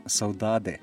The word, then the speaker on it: saudade: So, you know, it's been said that the sensibility of your music partly comes from saudade. This is, I don't know if it's pronounced saudade or something. – Saudade is okay.